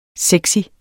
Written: sexy

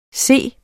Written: set

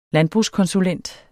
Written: landbrugskonsulent

